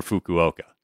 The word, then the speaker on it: Fukuoka: But I'll save the rest for Fukuoka.